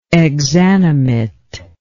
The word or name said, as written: exanimation